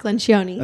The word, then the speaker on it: Lencioni: Patrick Lencioni.